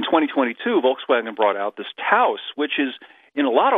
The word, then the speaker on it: Taos: Taos.